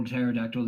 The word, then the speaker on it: pterodactyl: Just you try to spell pterodactyl these days and you need Google to do it. – Fucking starts with a P for some fucking reason. Explain that to me, Pencil. – The P is silent.